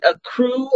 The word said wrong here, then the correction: Accra: Where are you again? What is it called? – Accra. Accra.